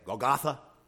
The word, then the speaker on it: Golgotha: Sometimes people say it Golgotha. The actual Hebrew pronunciation is Golgotha.